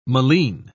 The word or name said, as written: malines